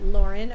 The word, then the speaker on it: Lauren: Okay, you can find me on Instagram at Lauren Rudick. It's spelled Lauren, L-A-U-R-E-N.